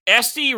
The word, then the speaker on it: Esty: Esty rhymes with testy.